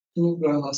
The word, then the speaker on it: Nugroho: Nugroho.